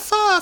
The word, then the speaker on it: pho: So, I ordered that, and then my friends got there, and then we were all like, yeah, pho, pho, pho, and we were all really excited. It's spelled P-H-O, so if you're Googling what pho is, and nothing's coming up, that's why. P-H-O. It's Vietnamese.